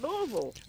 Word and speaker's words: Louisville: And you said Louisville. – I said it correctly.